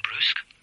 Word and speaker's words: brusque: Well, you sound brusque. – That has to be Jenny Oliver. – How did he know? – Who else would pronounce brusque the French way?